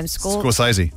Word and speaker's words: Scorsese: Scorsese.